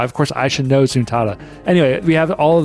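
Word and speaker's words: Zuntata: And we also have the one from Taito, pronounced Zuntada.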